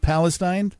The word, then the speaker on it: Palestine: So pronunciation on the town name, is it Palestine? – You're spot on.